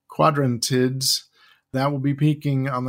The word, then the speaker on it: Quadrantids: The Quadrantids. That wasn't terrible. – That was maybe your best ever. I mean, that so much for this planetary radio holiday tradition of hearing you attempt to pronounce it. I think you got it.